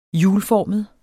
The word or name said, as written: hjulformet